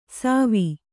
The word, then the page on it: ಸವಿ: kannada